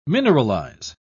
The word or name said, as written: mineralize